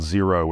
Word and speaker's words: Xero: I'm partial to a brand called Zero, which is spelled X-E-R-O.